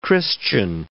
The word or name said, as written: Christian X